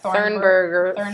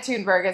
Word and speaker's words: Thunberg: Greta Thunberg is her name, and I said it wrong last week, so I'm correcting myself.